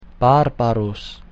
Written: barbarus

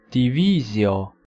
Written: divisio

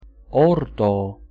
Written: ordo

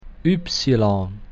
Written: ypsilon

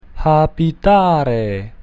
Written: habitare

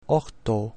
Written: octo

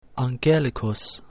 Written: angelicus

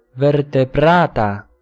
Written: vertebrata

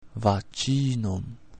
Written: vaginum

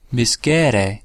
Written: miscere

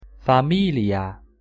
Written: familia

